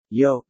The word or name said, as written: yolk